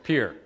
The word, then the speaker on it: Pierre: So I went from there to Pierre, South Dakota, which, by the way, is another French word. – Yeah, it's Pierre.